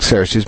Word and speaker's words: Xerxes: But we pronounce it Xerxes, but remember that that X letter, is chai, is really something more like ch, or kh.